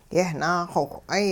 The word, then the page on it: yehnaaxowh'ay: hupa